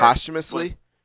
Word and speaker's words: posthumously: How do you say that word? – Posthumously. – Posthumously. Okay, yeah. I'll never forget it now.